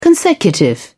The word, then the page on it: consecutive: British English